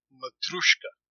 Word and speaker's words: Matryoshka: And she introduced us to the Russian nesting doll, and she pronounced it Matryoshka.